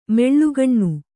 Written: ಮೆಳ್ಳೆಗಣ್ಣ

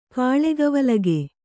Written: ಕಾಳಗವಲಗೆ

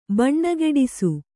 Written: ಭಾನಗಡಿ

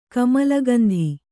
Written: ಕಮಲಗಂಧಿ

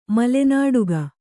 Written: ಮಲೆನಾಡುಗ

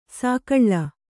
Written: ಸೊಗುಳಿ

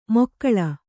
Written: ಮೊಕ್ಕಳ

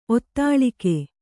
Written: ಒತ್ತಾಳಿಕೆ

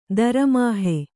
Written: ದರಮಹಾ

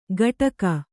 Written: ಗಟಕ

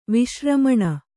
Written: ವಿಶ್ರಮಣ

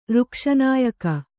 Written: ಋಕ್ಷನಾಯಕ

